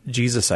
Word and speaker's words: Jesu: In our chapel, we have a Divine Mercy portrait, and then the Jesus, I Trust in You is in Latin. – Uh-huh. – And there it's J-E-S-U.